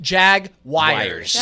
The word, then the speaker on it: Jaguars: Do you pronounce it Jaguars because you're trying to be funny, or is that how you think it's pronounced? – I think she does it because I do it. That's the way we Jaguars? – Pronounce it in Florida.